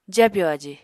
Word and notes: jabyò aji: apatani

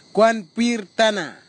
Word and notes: kwanpir tanna: kwamera; Tanna

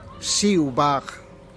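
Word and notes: shiu' bag: Teotitlán del Valle Zapotec

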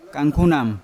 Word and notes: kangkunam: Yawa

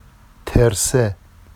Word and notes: terse: Vafsi

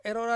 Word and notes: rora: Ansus

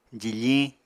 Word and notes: jifiñ: apatani

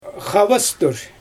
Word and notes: xabastur: Xyzyl

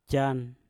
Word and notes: jan: Mpur

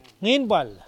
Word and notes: ninpal: Mokilese

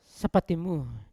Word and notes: sapatimu: Yawa

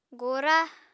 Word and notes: gora: Apatani

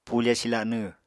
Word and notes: pufe silanw: apatani

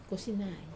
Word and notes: kosinai: Wamesa

